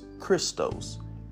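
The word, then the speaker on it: Christos (Χριστός): Christ is a Greek word pronounced Christos, and it means the anointing or anointed.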